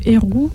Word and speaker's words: erhu: Oui, alors c'est le Eru. ça s'écrit E-R-H-U.